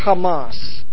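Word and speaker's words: Hamas (חמס): The word violence, this is Strong's number 2555, and the way you pronounce the word in Hebrew is Hamas.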